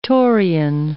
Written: Taurean

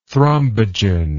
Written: thrombogen

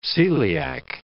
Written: coeliac

